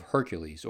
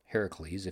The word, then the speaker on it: Heracles: The Spartans saw themselves as descendants of Hercules, or Heracles, if you like the Greek pronunciation better.